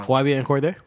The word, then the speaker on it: Cordae: YBN Cordae.